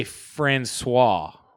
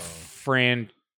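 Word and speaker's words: François: You say Francois, not Franchoice.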